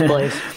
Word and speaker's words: Blaise: We were reporting it as it's Blaise, right? I probably said his name wrong. My bad. – It is Blaise.